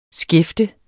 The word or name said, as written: skæfte